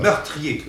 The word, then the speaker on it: meurtrier: Meurtrier. Oui. Et actuellement, c'est trois syllabes.